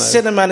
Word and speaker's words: Sinnerman: It's spelled S-I-N-N-E-R-M-A-N. – The Cinnamon. – The Cinnamon. It rhymes with Inner Man, which is kind of appropriate, really.